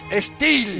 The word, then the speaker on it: STIHL: Steele se escribe S-T-I-H-L.